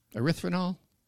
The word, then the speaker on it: erythritol: Oh, it's like say it like it's French. Erythritol. – Erythritol. – There you go. That's how you say it right.